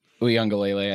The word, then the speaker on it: Uiagalelei: Uiagalelei, I think it is. Uiagalelei. Thank you. I always miss a syllable in there.